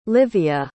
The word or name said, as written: lyvia